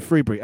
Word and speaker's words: Frebury: Ryan Freebury, FREBURY.